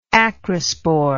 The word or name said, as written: acrospore